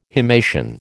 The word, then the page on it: himation: American English